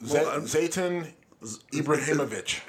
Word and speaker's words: Zlatan Ibrahimovic: Zlatan Ibrahimovic.